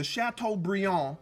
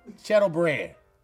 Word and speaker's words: Chateaubriand: Uh, you like, let me get this chateau, chateau brand. That sounds like some some trail mix. Yeah, that sounds good. Let me get the chateau brand.